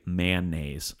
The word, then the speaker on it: mayonnaise: I'm saying mayonnaise. – Mayonnaise. – And I might throw a little hint of may in there. Mayonnaise. – Mayonnaise. – I'm not saying mayonnaise, no. Dude. It's two syllables to me.